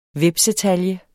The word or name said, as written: HVEPSETALJE